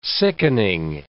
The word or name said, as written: sickening